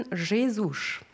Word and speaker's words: Jesus: Sie hören jetzt unser einziges Stück heute für Posaune Solo, komponiert von Nelson Jesus. Also er schreibt sich wie Jesus. Aber ich habe heute gelernt, mit den ganzen Landsleuten Jesus spricht man es.